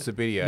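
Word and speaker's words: Subbuteo: Yeah, there's a game called Sabuteo.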